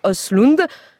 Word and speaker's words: Östlund: Contrepoint à lire cette fois dans Libération sous la plume de Julien Gester qui dénonce, lui, la farce lourdingue de Ruben Östlund. Un mot sur la prononciation, Patrick? Non, non. – On dit bien.